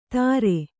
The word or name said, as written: ದರೆ